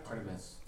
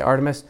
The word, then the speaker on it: Artemis: Artemis? I'm blanking on it now. – Artemis. – Right? Did I say it right? What did you say? Okay, Artemis.